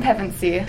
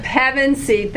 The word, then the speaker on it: Pevensey: And this is your first role as the eldest sister, Susan Pevensey. I hope I said it right now. – Pretty close. – Okay, you say it, Susan. – Susan Pevensey. – Pevensey, thank you.